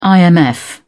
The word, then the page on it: imf: British English